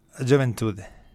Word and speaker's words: Juventude: Juventude.